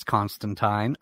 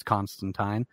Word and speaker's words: Constantine: That's a funny thing because there's the difference in pronunciation. Neil Gaiman will tell you it's Constantine. I believe Alan Moore will tell you it's Constantine. – Yeah. – But I've always said Constantine. My justification is that I also don't say aluminium.